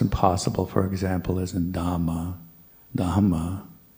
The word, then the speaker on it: Dhamma: And then H as in hot or have should always be pronounced. So do try to pronounce all H's in Dhamma, Buddha, Sangha. And that's always difficult because, as I said before, in English we usually just skip over H's when they're next to a consonant. So Buddha, Dhamma, Sangha.